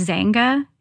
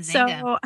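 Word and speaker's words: Xanga: I think it's pronounced Zanga. It was X-A-N-G. – Yeah, Zanga.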